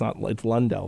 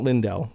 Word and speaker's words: Lundell: It's Lundell, though. – Lundell. – So not Lindell. If you're pronouncing it like me, you're talking about the Dallas defenseman.